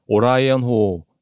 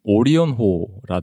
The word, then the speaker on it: 오리온호: 그리고 또 하나는 제가 오라이언호 라는 게, 어, 이 책을 통해서 알게 된 거는 오리온호라고 더라 하더라고요. – 그러니까 음, 오라이언이 아, 영어 네. 발음이었던 거예요. 아, 제가 그 오라이언이 오리온의 영어 발음인지 몰랐던 거죠.